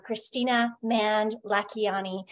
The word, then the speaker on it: Kristina Mand-Lakhiani: I'm so excited to have our amazing guest here, Kristina Mand-Lakhiani, co-founder of Mind Valley, mother, philanthropist.